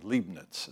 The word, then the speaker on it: Leibniz: This expression, problem of evil, first was used by a German, he was actually a German philosopher and mathematician named Leibniz is how you pronounce his name, and Leibniz is how you pronounce the name.